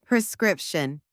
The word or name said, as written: prescription